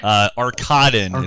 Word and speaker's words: Arkadin: Arkadin. It's actually an emphasis on the second syllable.